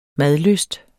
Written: madlyst

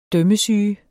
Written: dømmesyge

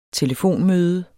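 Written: TELEFONMØDE

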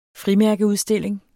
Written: frimærkeudstilling

